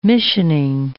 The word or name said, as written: missioning